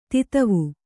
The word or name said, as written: ಧತ್ವ